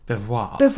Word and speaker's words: Belvoir: So that's from Now, I think we call it Belvoir, but last time we pronounced it Belvoir when we were doing the Craft Gin Club video, we got some shtick because it's actually beaver or something. – Belvoir? Look, it's spelled B-E-L-V-O-I-R. It's Belvoir.